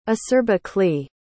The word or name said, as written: acerbically